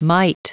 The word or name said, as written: mite